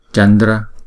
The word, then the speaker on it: Chandra: Chúng ta phải đọc phải có chữ D và chữ R trong đó. Chandra.